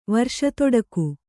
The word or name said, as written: ವರ್ಷತೊಡಕು